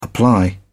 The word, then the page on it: apply: British English